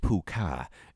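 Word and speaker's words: púca: The meaning of puka, pronounced puka, is from the old Irish word puka, which means goblin. And there are many variations of spelling of puka, including P-U-fada, P-I-L, P-H-U, fuka, you fuka.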